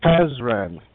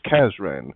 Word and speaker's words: Kazran: I think it's Krasdan. Is it Krasdan or Kazdan? – Kazran Sardig, I think. – Kazran. I'll say it a few times. Kazran.